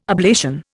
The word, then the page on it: ablation: Indian